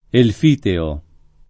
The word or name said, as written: Elfiteo